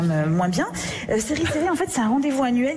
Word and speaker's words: Série Series: C'est comme ça qu'il faut prononcer prononcer à la française, série, série.